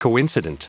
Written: coincident

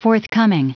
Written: forthcoming

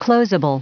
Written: closeable

